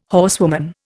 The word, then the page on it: horsewoman: Indian